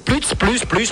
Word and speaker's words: Plutz: Et ça, moi, j'ai une réservation au nom de monsieur et madame P-U-T-Z. – Non, vous avez encore mal compris. C'est plus, la famille plus. – Mais j'ai pas de réservation à ce nom-là. – Mais c'est ça, c'est plus. Ah, moi, je prononce ça comme ça, c'est ça. Quand vous m'appelez, madame Valentin, oui. – P-L-U-T-Z.